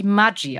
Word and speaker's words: Maggi: Da habe ich auch selber gelernt, dass Julius Maggi eigentlich ein Norditaliener, nee, ein Schweizer war er, glaube ich, aber mit norditalienischen Eltern und eigentlich Maggi ausgesprochen wird.